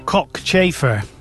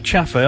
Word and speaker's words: cockchafer: So Vanessa says, oh, this is quite a while ago, she pronounces cockchafer. – Oh, not chaffer. I think you're right. Yeah, you're right, because there's only one F.